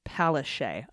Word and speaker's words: Palaszczuk: pronounced Palaszczuk. Okay. totally off base there, Palaszczuk. – That was awesome, though, that there was a correction right after you were like, Palaszczuk. – Palaszczuk. Nope. Palaszczuk.